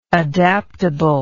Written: adaptably

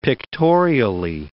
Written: pictorially

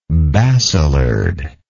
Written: basilard

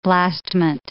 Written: blastment

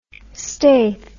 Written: staith

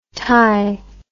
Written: tye

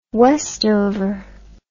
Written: Westover

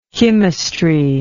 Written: chymistry

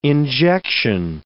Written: injection

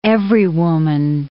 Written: everywoman

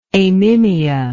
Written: amimia

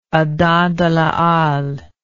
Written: Adam de la Halle

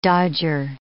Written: dodger

